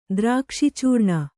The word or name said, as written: ದ್ರಾಕ್ಷಿಚೂರ್ಣ